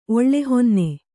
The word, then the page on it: ಒಳ್ಳೆಹೊನ್ನೆ: oḷḷehonne